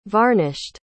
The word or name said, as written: varnished